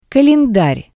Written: kalendar

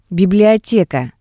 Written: biblioteka

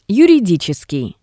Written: yuriditseskii

